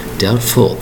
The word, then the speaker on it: doubtful: Doubtful. Now, please mark the spelling. D-O-U-B-T-F-U-L. Only one L in there, and there's a letter B in there. It's silent. Again, it's spelled D-O-U-B-T-F-U-L.